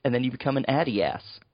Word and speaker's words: Adidas: Thanks to, is it Adidas? Is that how it's pronounced? – It's Adidas. – Oh, Adidas. Okay.